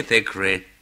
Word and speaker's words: Thackeray: Vous connaissez Thackeray? Ah, T-H, T-H, c'est bien comme cela qu'il prononce, hein? Thackeray, c'est bien cela, c'est bien comme cela qu'on dit.